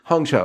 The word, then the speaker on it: Hong Chau: Hong Chau.